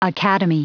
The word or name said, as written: academy